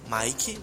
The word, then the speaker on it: Mike: Você vai buscar aí Mike Oliveira, é diferente meu nome, é M-I-K-E, Mike, mas é como se escrevesse Mick, Mike Oliveira,